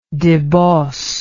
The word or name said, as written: deboss